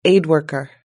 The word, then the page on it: aid worker: British English